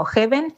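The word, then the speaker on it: Häven: Fue Gabriel quien propuso el nombre, que se pronuncia como Heaven, cielo, pero que se escribe Haven, con la A con diéresis,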